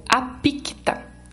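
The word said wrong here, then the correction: Aquipta: Acho que eu falei certo. Aquipita. – Isso. – É o Aquipita.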